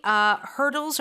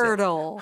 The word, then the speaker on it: hurdle: 근데 발음이 완전 허들이 아니라 들려주세요. – Hurdle.